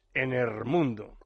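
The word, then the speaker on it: En er mundo: Un ejemplo de ello es Enermundo, así pronunciado, porque los autores lo escribieron con la grafía que corresponde a la pronunciación andaluza, el artículo el convertido en er, en mundo.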